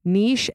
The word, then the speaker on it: niche: The word niche, N-I-C-H-E, niche and niche, both correct. There's not an incorrect way to say that. Just like data and data.